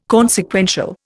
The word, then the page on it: consequential: Indian